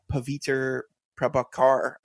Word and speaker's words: Pavitr Prabhakar: Kind of moving on from Miguel to another Spider-Man we get in this movie, a new character, Spider-Man India, Pavitr Prabhakar, I think is how you pronounce it. My apologies. – Yeah, it sounds pretty good to me.